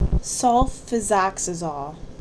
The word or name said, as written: SulfiSOXAZOLE